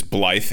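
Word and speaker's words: Blaidd: I respec'd into a strength intelligence build, and I used what is called the Royal Greatsword, which is Blythe, if you've met him, Blade, as it's spelled, but it's Blythe, the wolf guy in the trailers.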